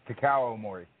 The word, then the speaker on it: Takao Omori: I was looking at some of the names, and I saw like a former 0-1 heavyweight champion, Takao Omori. I'm probably butchering his name there. – No, it's Takao Omori.